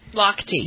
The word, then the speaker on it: Lochte: Swimmer Ryan, is it, how do you pronounce that name? L-O-C-H. Lochte. – Lochte, yes.